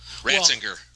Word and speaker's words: Ratzinger: But they said the people that were the guy that was the head of the CFR was Cardinal Ratzinger. Is that how you say his name? – Ratzinger.